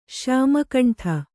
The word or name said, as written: ಶ್ಯಾಮಕಂಠ